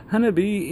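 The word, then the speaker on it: Hanabi (花火): Hanabi is Japanese for fireworks.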